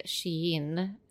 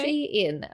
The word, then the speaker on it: Shein: Shein. – Ja. Uh, because she's in style. Shein.